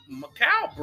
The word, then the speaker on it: Mikal: The first three quarters of this game was entertaining as Mike Mikal Bridges. I finally pronounced that shit right. I was always wondering if it was Michael Bridges or Mikal Bridges, but fuck that.